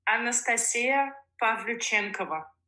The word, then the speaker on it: Pavlyuchenkova: Okay, say the name correctly for everybody so they know. – Anastasia Pavlyuchenkova. – Okay. Thanks for that. I'll remember that next time I do one of your matches. – That's the Russian pronunciation, but you said it really, really good.